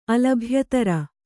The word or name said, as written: ಅಲಭ್ಯತರ